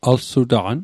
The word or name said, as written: alsutaqan